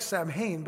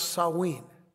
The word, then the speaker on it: Samhain: One was called Beltane, B-E-L-T-A-N-E, Beltane, and the other was Samhain. It looks like Samhain, but it's not. It's pronounced Samhain.